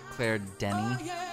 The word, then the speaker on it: Claire Denis: I think the S is silent. – Claire Denis.